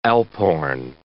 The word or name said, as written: alphorn